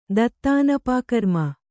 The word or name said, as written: ದತ್ತಾನಪಾಕರ್ಮ